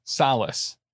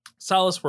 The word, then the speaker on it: Solis: but Eva Longoria from Desperate Housewives, who played Gabrielle Solis, I believe is how you pronounce that. – Solis. – Solis works too.